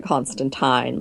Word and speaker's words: Constantine: Yeah, I've been one thing that James and I were talking about is, honestly, with I know you're supposed to pronounce it Constantine, like the time of a fork,